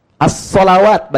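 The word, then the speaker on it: As-salawat (الصلوات): As-salawat bacaannya. Alif, lam, sod, lam, waw, alif, ta, maftuha. As-salawat dibacanya.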